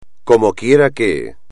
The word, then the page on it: como quiera que: espagnol